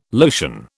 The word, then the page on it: lotion: British English